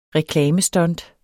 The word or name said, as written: reklamestunt